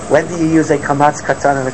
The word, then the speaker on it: kamatz: What is the difference between a kamatz and a patach? Right? Kamatz is an aw sound.